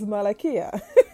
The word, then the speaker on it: Malakiyah: I would have known that the pronunciation is Malakiyah.